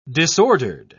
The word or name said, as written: disordered